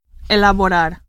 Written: elaborar

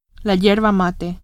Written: yerba mate

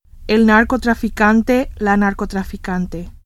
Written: narcotraficante

